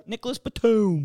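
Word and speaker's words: Nicolas Batum: Nicholas Batum.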